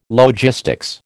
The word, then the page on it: logistics: American English